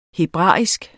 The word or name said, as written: hebraisk